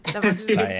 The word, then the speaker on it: pâtisserie: No sé si lo pronuncié bien, pero la pâtisserie.